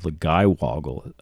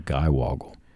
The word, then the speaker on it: Giwoggle: I would be very happy to read about it, but looking at it, I, I would pronounce that Woggle, not Guy. It's G I W O G G L E. So I think Guy Woggle. – Well, yeah, if you pronounce it in American English, but most of the stuff with stuff like this is pronounced phonetically, right? And the GI would be gee.